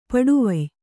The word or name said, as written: ಪಡುವಾಯ್